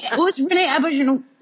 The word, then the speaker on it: Auberjonois: Renee Auberjonois? Is that how you say it? – Yeah.